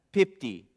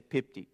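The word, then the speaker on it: pipti (πίπτει): However, this word used for fails, pipti, is the Greek word. That's how you say it. Say pipti.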